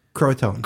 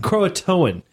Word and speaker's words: Croatoan: Croatoan. – Croatoan.